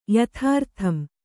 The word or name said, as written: ಯಥಾರ್ಥಂ